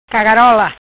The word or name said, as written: Cagarola